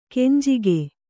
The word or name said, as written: ಕೆಂಜಗ